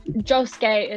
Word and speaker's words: Josuke: I don't know why I called him because Josuke is how it's pronounced in Japanese.